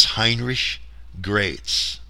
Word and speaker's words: Heinrich Graetz: His name is spelt G-R-A-E-T-Z. Proper pronunciation is Heinrich Graetz.